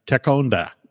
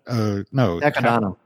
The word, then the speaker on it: Takodana: You know, her castle back on Takonda. Takodana.